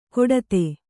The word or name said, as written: ಕೊಡತೆ